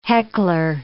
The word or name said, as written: heckler